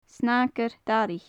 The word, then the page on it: snagair-daraich: gaelic